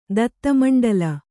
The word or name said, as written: ದತ್ತಮಂಡಲ